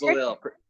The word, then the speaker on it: Belisle: It's Belisle. Pretend the S isn't there.